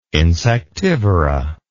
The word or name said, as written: insectivora